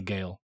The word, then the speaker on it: Gaal: I think it's Gale. Yeah. It's spelled G-A-A-L. – Yeah. – But it's definitely Gale.